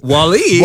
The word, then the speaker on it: WALL-E: Emphasis on the wall. WALL-E.